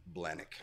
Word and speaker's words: Blahnik: Blahnik.